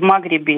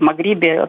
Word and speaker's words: Магриб: Вот кто как себя ведет, мы сейчас видим совершенно блистательно, потому что, например, Франция, которая имела всегда свои интересы в Магребе или в Магрибе, так сказать, там все время меняется ударение, и в странах залива, она себя чувствует относительно комфортабельно.